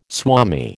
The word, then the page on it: swami: American English